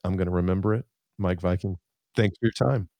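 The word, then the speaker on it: Meik Wiking: His name is spelled, by the way, M-E-I-K space W-I-K-I-N-G. Mike Viking.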